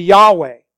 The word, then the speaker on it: Yahweh: The name, if pronounced, would be Yahweh.